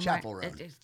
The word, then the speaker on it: Chappell Roan: You know, like Chappelle Roan. I mean, yeah. – Did I say her name? – Chappell Roan. – Chappell. What? I'm thinking Dave Chappelle.